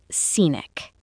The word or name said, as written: scenic